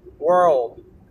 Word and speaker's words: world: これが D の発音なんですけど、ワールドに関して言うと、前歯の後ろにつけた下を離すまでが D の発音っていう形になってるんですよね。 world, world.